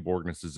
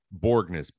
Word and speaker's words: Borgnes: It's Norwegian. And there's like 3 Borgneses in the world. – Wait, what is it? – Borgnes, B-O-R-G-N-E-S. – Borgnes. – Yeah, one of the hardest to hear and pronounce.